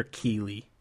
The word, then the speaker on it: Keighley: Is it Keighley or Keighley? – Keighley. – Keighley. I never know how to pronounce the guy's last name.